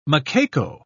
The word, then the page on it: macaco: British English